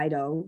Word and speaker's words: eido: Okay, so what I did is I looked up the words, the Greek words for know, K-N-O-W, that's used most frequently in the New Testament. And the words were E-I-D-O, which I think is pronounced Ido or Ido, which means to be aware, to be sure, perceive, understand. And then ginosko, G-I-N-O-S-K-O, which also means being sure, but it threw in another word, absolutely understand and know.